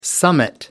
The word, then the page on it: summit: American English